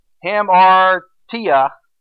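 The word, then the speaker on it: hamartia (ἁμαρτία): I looked up the word sin in that place right there in the Greek is pronounced hamartia, and the descriptions are offense, to miss the mark, and to not share in the prize.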